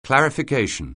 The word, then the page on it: clarification: anglais